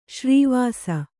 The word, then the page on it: ಶ್ರೀವಾಸ: śrī vāsa